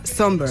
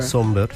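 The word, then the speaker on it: Sombr: Pues mira, yo busqué cómo se pronunciaba por si acaso, pero efectivamente se pronuncia Somber. – Somber, sí.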